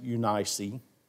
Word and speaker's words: Eunice: His mother's name was Eunice. Some people pronounce it Eunice. Eunice, and his grandmother's name was Lois.